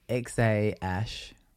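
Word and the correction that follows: X Æ: No, X-Ash. The A-E is pronounced Ash.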